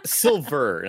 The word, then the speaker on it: Silver: And just the thought that at some point we might get a Chosen versus Terry Silva fight is weird and exciting to me. – It's Silver, not Silva.